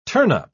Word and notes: turnup: British English

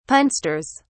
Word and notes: punsters: British English

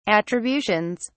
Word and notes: attributions: British English

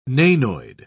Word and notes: nanoid: British English

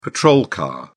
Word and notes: patrol car: British English

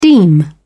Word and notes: deem: British English

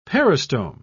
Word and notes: peristome: British English

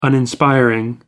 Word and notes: uninspiring: American English

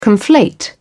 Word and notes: conflate: British English

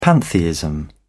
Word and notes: pantheism: British English

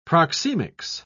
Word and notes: proxemics: British English

